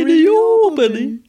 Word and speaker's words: New Albany: Nej, Neobody. – Det är så de uttalar det själva.